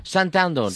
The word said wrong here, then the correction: Standerton: Standerton. – Standerton. It's difficult to pronounce. It's Standerton.